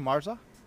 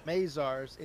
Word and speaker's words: Mazars: How do you say that name? Marza? – Mazars,